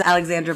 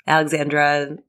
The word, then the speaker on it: Alexandra: My name is Alexandra Blair from the Silk War. Alexandra, the four syllables for sure, because it's a power move thing.